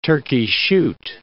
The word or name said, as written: turkey shoot